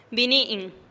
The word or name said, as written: bini'in